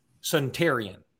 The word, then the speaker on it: Suntarian: It is pronounced, and I got this straight from Joseph Hastings, who asked him personally, Suntarian. I know it's not spelled that way.